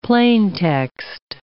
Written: plaintext

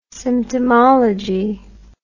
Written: symptomology

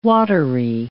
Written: watery

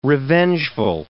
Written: revengeful

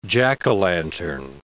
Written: jack-o'-lantern

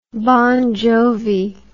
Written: bon jovi

